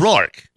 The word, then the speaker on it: Roark: Roark. – Roark. Yeah. Roark. – Just like me. I'm saying too much A in it. It's yes. Just Roark.